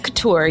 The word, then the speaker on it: couture: Am I saying it right? – It's couture, yeah. Couture is basically high fashion.